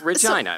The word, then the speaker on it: Regina: Yeah, it's spelled Regina, but it's pronounced Regina.